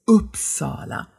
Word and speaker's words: Uppsala: Im Schwedischen ist es ein etwas offenes U. Wenn ich meinen Anfängern, in den Anfängerkursen, die schwedische Aussprache näher bringe, dann sage ich ihnen immer, stellt euch mal vor, euch haut jemand so richtig in den Solarplexus rein. Und der Laut, den ihr dann ausstößt, das ist das U. Ne? Und deshalb ist es Uppsala. Die Betonung ist tatsächlich erstmal auf der ersten Silbe, eben auf diesem Upp, aber es ist kein Uppsala,